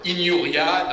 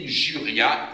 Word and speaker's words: injuria: injuria, normalement il n'y a pas de J, mais on est d'accord, pour simplifier, injuria, I-N-J-U-R-I-A, d'accord, I-N-J-U-R-I-A, l'injure.